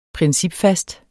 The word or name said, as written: principfast